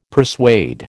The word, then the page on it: persuade: American English